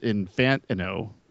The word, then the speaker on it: Infantino: Well, first, Scott, I don't know if you realize that it's pronounced Infantino, according to Bill. So, you're driving me nuts saying it the other way that whole time.